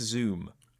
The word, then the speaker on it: Zum: His name is spelt Zum, but if you look very closely, there's an umlaut over the U. – Okay. – So it would be pronounced Zoom.